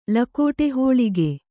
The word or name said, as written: ಲಕೋಟೆಹೋಳಿಗೆ